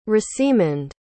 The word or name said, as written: rosiemund